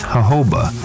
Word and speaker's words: jojoba: This edition of The Natural Medicine Chest will discuss jojoba, the miracle plant of the desert. – Jojoba is spelled J-O-J-O-B-A, but is pronounced according to its Spanish origins.